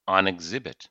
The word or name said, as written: on exhibit